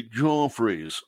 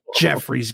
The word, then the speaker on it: Joffrey's: I say Joffrey's. Say, good fellow, would you like a Joffrey's? – It's not Jeffrey's, Garrett. It's Joffrey. – Joffrey. Jeffrey is some guy.